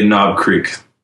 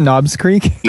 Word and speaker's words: Knob Creek: Knob's Creek or Maker's Mark Bourbon? – Well, I'd say Knob Creek. – Knob Creek? – Yeah. – It's not Knob's Creek? – No, just Knob.